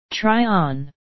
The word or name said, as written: try on